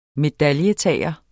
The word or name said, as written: medaljetager